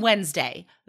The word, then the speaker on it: Wednesday: The wedding is on Wednesday because Wednesday, it sounds like it's N-D, but it's not. It's spelled W-E-D and then Nesday. So you just remember that that D goes first.